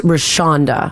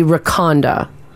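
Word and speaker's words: Rachonda: But the CH is Rashonda. – Oh, okay. – Or soft. If you were to make, it would be Rakonda.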